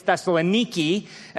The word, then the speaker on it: Thessaloniki: It's pronounced differently. It's Thessaloniki, ends with an I now, but and I don't know why the Greek language shifted that, but Thessalonica is what it's called in ancient times.